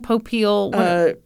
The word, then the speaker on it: Popeil: Well, that Ron Popeil, I mispronounced it, didn't I? – No, you pronounced it right.